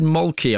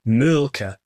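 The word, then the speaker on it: Mölkky: We use Molky. Most of the people we play against use Molky, but the Finns have a very unusual pronunciation, which, please forgive me, I'll try it. It's Mulka. So I think that's, something a bit more like that. But to us, it's Molky.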